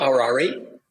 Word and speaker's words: Harari: Harari. – Yuval Harari. – H-A-R-A-R-I.